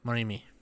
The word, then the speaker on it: Morimi: Morimi, there we go.